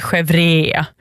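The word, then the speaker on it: chèvre: Chevre, säger man. Det är ju inget e i slutet där.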